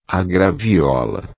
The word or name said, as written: graviola